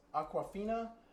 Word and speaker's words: Awkwafina: As is, and I'm probably going to butcher this name, but it's Awkwafina.